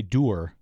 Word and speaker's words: dour: I think it's dour, D-O-U-R. But I hear people say doer.